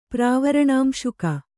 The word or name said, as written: ಪ್ರಾವರಣಾಂಶುಕ